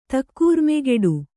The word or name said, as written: ತಕ್ಕೂರ್ಮೆಗೆಡು